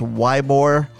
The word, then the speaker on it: Ybor: And for those of you who are not from Tampa, it is not pronounced Ybor. Shout out to you know who you are. Much love for you, kid. But we went to Ybor.